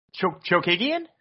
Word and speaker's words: Chookagian: Chookagian? – There you go.